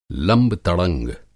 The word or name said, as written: लंब-तड़ंग